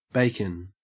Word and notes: bacon: Australian English

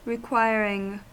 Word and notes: requiring: American English

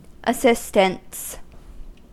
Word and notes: assistants: American English

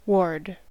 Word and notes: ward: American English

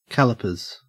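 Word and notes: calipers: au Australian English

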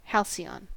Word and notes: halcyon: American English

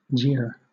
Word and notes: jeer: British English

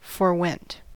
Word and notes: forewent: American English

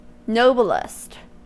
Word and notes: noblest: American English

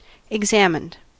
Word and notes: examined: American English

